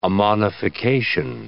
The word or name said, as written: ammonification